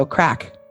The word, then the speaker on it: craic: This one I actually already know, so crack. – That I don't know. – So the sentence that I learned it in is, it was great crack. – Well, now. – It's spelled C-R-A-I-C.